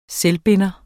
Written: SELVBINDER

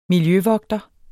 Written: miljøvogter

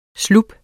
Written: slup

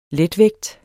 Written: letvægt

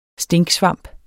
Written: Phallus impudicus

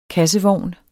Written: KASSEVOGNE

